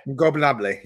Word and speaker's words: Ingobernable: Ingobernable. Yeah, you got to say it fast.